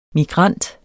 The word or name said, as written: MIGRANT